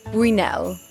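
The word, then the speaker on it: Buñuel: I looked it up. It's a Bunel, I think.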